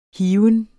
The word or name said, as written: hiven